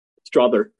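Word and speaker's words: Strawther: Strother.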